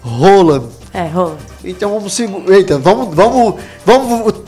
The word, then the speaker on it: Haaland: É porque eles pronunciam Roland.